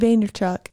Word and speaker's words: Vaynerchuk: Vaynerchuk.